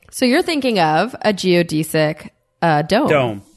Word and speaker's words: geodesic: Okay, so the word this week is another frustrating word that is pronounced two different ways. I'm going to go with the consensus pronunciation, which is geodesic. Although I personally would like to say geodesic, which you can say too.